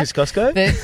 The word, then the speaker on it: Kosciuszko: Kosciuszko? – That's how you're supposed to pronounce it. It's Polish.